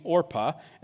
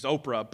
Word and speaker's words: Orpah: By the way, yes, Oprah Winfrey is named after Orpah. Her family just didn't know the story well, and so they mispronounced it, and she's Oprah, but she's really named after this person here.